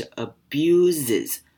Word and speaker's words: abuses: 好 or sexually 这边 X 呢发 sexually 可以想象成 sec 跟 sexually 好，所以这三个字念法都不一样哦，分别是 physically 还有 emotionally 第三个字是 sexually 好，最后一个重点呢是 abuses 这个字 abuses 它的两个 s 都要念成 z 的声音，所以是 abuses you 好，那我们来复习今天的例句。